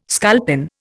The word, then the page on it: sculpin: Indian